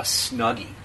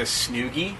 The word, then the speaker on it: Snuggie: You had a story about a Snuggie, and you didn't know what a Snuggie was, and you pronounced it as a Snoogie. – Yeah. – And I corrected you on the air, and you got so mad at me.